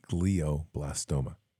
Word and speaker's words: glioblastoma: It is glioblastoma, pronounced glioblastoma.